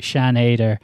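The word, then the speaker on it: Sian Heder: Yeah, I mean, Shan Hader, Okay, I keep wanting to. There's different pronunciations of her name, but I checked it out. Shan Ader.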